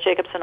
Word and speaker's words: Jakobsen: Janet Jakobsen, all one word, .org.